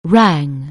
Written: rang

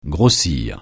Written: grossir

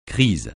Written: crise

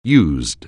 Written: used

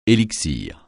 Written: élixir

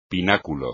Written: pináculo

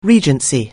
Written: Regency

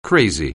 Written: crazy

